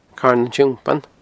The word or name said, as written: Carn an Tiompain